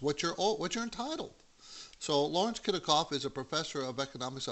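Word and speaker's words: Kotlikoff: Is that how you say it? Kotelikoff? – Close enough.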